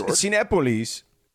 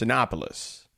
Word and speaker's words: Cinépolis: It's Cinépolis is how you say it in Spanish. In English, it would be pronounced Cinépolis.